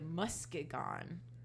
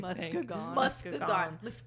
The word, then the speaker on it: Muskegon: I just don't want people from Michigan getting mad at us for you mispronouncing everything. – Muskegon. All right, yeah. I got it.